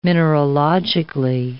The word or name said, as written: mineralogically